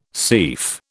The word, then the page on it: seif: British English